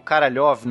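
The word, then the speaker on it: Karalev (Королёв): então a gente vai ter aqui o Sergei Korolev ou Karalev na, na pronúncia russa.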